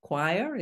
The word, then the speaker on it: Choir: unique way of doing that through your company, Choir. Is that yes. – The right pronunciation? – Mm-hmm.